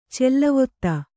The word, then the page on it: ಚಲ್ಲವತ್ತ: Kannada